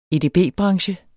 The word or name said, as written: edb-branche